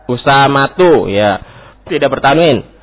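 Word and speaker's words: Usamatu (أسامةُ): Usamatun, Usamatun. Nah, ini juga salah. Ya, Usamatu. Usamatu. Nah, ini. Sehingga bukan hanya memberi nama, tapi harus tahu juga cara baca nama anaknya ini. Kalau di akhir marbutoh, maka tidak bertanwin.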